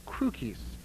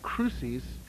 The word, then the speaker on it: crucis: The last word is pronounced crucis in ancient Latin and crucis in what is known as church Latin.